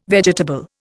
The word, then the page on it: vegetable: Indian